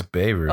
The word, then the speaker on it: Beirut: I think it's Beirut. – Oh, yeah, Beirut. Beirut. Yeah, I'm pronouncing it wrong. Beirut. I'm listening to it right now, then. Beirut. That's how you pronounce it.